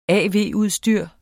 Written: av-udstyr

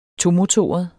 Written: tomotoret

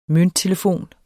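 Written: MØNTTELEFON

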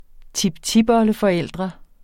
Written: tiptipoldeforældre

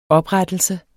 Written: oprettelse

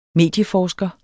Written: medieforsker

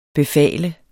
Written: befale

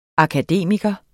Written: akademiker

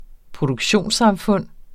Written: produktionssamfund

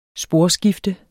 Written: sporskifte

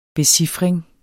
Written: becifring

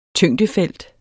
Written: tyngdefelt

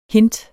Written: hint

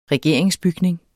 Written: regeringsbygning

